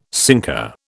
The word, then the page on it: sinker: British English